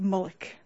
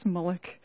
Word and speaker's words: Mulock: And you could pronounce that Mulock or Mulock or however you might want to say it, but I like to pronounce it Mulock, Miss Mulock, and which gives a bit more of an ominous, mystical connotation, Mulock.